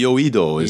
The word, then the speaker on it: Yoido: Yodo? Yoido? How do you pronounce it? – Yoido is how Yoido. I was taught.